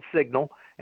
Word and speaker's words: Cygnal: And yeah, we use a company called Signal, and it's not spelled like the word signal, but it's one of these funky modern day spellings, C-Y-G-N-A-L. The company's Signal, they conduct the Civitas poll for us, and they do it in a very scientific way.